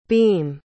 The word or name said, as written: beem